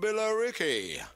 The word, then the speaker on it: Billerica: So it's B-I-L-L-E-R-I-C-A. – Billerica. – Billerica. But they got rid of the E. They just, that just gets dropped from the pronunciation. So they'd lose a syllable, Billerica.